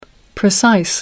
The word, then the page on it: precise: American English